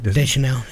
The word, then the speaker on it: Deschanel: Her and Winona and Zoe Deschanel, am I pronouncing it right? – Deschanel, yeah.